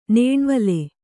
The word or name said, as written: ನೇಣ್ವಲೆ